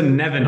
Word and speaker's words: Neven: Und bitte Neven aussprechen. – Ja, Neven, oh, schon der erste Fauxpas. Ja. Alles klar, herzlich willkommen, Neven Subotic.